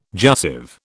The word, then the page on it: jussive: British English